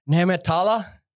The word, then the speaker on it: Nehmetallah: I'm going to say Nehmetallah. How close? – 99%.